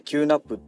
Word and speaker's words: QNAP: キューナップって読むのかなえー、 QNAP って書いてね、キューナップっていうね、